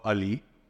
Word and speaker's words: Ali: You find me on YouTube with my name, Vaibhav Ali, which I'm sure most of them over here will be a... – Spell that for me. It is Vaibhav space Ali, A-L-I.